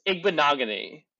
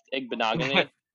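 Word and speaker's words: Igbinoghene: Noah, it's Igbinogany. That's how you pronounce it. I've been saying it wrong the entire time also, and then I heard Trey Wingo say it during the draft. Igbinogany.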